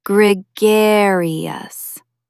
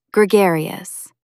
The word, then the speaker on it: gregarious: Stress the second syllable. Gregarious. Try saying it. Gregarious.